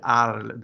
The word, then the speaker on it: Arles: Alltså det står AC, ja, det, står, det stavas A-R-L-E-S. Arl.